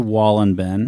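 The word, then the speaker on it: Wohlleben: But at the same time, this guy who does the book, Wallenberg, I think is Wallenberg? I can't remember his name. He's a German man, and I keep on saying his name wrong. – Peter Wallenben. – Wallenben, that's it.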